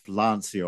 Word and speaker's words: Lancia: Well, Lancia are in the house, aren't they? This was the year of Lancia, or Lancia is the correct pronunciation, isn't it? Well, I was struggling with the Italian pronunciation before.